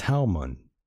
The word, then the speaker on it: Talmud: The Talmud? – The Talmud.